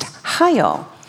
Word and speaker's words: chayil: And the word valor, actually the Hebrew word in the Old Testament for valor is spelled C-H-A-Y-I-L, and it's pronounced Hyl.